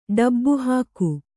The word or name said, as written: ಡಬ್ಬುಹಾಕು